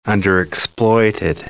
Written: underexploited

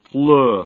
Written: fluf

